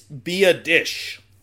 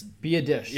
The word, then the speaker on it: Biadasz: First off, it's Biadasz. It's Biadasz. – Yeah, okay. which is totally not what you would think based Biadasz on how it's spelled.